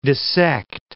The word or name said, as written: dissect